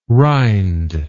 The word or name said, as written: rynd